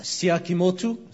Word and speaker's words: Tsiakimoto: My last name's pronounced Tsiakimoto. – Tsiakimoto. – Now that sounds Japanese. – Das klingt jetzt japanisch. – But as you can see, I'm not Japanese.